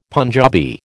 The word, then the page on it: Punjabi: American English